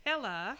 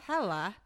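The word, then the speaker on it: Helle: But it's pronounced Hella. – Hella. – Because she is not from here. I believe she is Finnish. – Some sort of Scandinavian. – So it's H-E-L-L-E, pronounced Hella.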